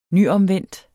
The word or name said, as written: nyomvendt